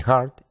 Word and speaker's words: heart: Aquí observa que la pronunciación correcta de heart es con una A y no con una E.